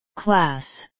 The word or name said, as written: class